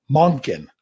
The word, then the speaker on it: Monken: Todd Monken. Did I say that right? – That looks about right to me.